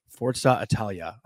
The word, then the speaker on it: Forza Italia: Forza Italia. I don't know how to fucking say that, but did I say that right? – Forza. Yeah, I mean, yeah, you said it fine.